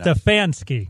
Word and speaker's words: Stefanski: Stefanski.